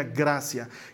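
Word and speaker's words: gratia: Ya de ahí en latín es gracia, Sí, se escribe con T, pero se pronuncia gracia.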